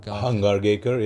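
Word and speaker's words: Hangargekar: So as much as I'd like to see, and I always pronounce him wrong, as you know, is it Hangrigarkar? – Hangrigarkar, yeah.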